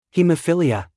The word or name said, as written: hemophilia